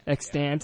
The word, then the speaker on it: Extant: The show Extant, is that how you say it? – Extant, yeah.